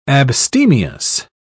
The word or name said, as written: abstemious